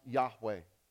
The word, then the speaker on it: Yahweh: Some people, they're Orthodox Jewish, very strict, and they're too afraid to say those four letters put together, which we would pronounce Yahweh.